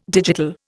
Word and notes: digital: Indian

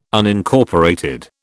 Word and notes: Unincorporated: British English

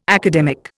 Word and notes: academic: Indian

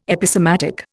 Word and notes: Episematic: Indian

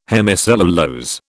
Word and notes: hemicellulose: British English

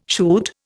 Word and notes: shoot: Indian

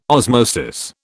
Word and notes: osmosis: British English

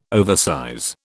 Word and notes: oversize: British English

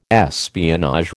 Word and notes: espionage: American English

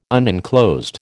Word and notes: unenclosed: American English

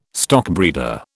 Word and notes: stockbreeder: British English

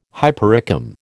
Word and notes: hypericum: American English

